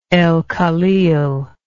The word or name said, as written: El Khalil